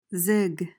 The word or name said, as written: zig